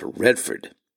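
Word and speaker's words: Vredefort: It is named after the town of Redford. Now, I'm going to spell that because it starts with the letter V. It's V, R-E-D-E-F-O-R-T, and it's pronounced Redford.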